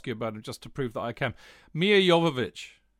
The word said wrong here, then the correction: Milivojevic: Wrong. – Oh, come on! I tell you what, actually, Nick, if you'd have heard me if you listened to Talk Sport when Alan Brazil tries to pronounce a Greek player, I made Brazil look good trying to pronounce Mija Jovovich. – Milivojevic, which I still can't pronounce now, as you correctly pointed out.